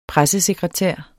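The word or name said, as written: PRESSESEKRETÆR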